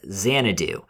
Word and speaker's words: Xanadu: Well, I did mispronounce the Citizen Kane. How it's Xanadu, which is also, you know, of course, set in Florida.